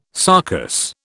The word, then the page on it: sarcous: British English